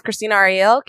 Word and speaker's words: Krystina Arielle: My name is Krystina Arielle, KrystinaArielle, spell it right or you get the wrong person.